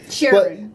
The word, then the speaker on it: Chiron: Like, they think of Bugatti, and they think of the Veyron, and the Chiron? I don't know how to pronounce it. – Chiron.